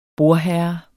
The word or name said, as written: BORDHERRE